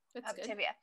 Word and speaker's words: Optavia: Optavia.